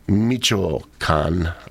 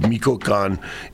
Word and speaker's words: Michoacán: The exhibit features masks and regalia from Michoacán's Michoacán. I'm sorry, I didn't say it right the first time. I said Michoacán. It's Michoacán. Winter festivals showcasing 5 dance costumes of those Mexican masks and 48 other masks that celebrate contemporary artistry with the deep cultural traditions.